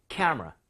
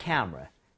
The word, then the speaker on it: camera: 이렇게 앞쪽에 강세를 주고, 두 번째는 camera. 얘도 마찬가지로 카메라 가는 게 아니라 camera. 이렇게 앞쪽에. Okay? So, 제가 두번 발음 들려드리도록 할게요, you guys. Instant camera.